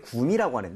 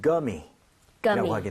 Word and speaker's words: gummy: 그런 것을 이렇게 표기하는데 대신에 우리는 구미라고 하는데 영어에서는 gummy라고 하게 됩니다. Gummy, right? So 구가 아니라 g에 가깝게 발음을 하셔야 됩니다.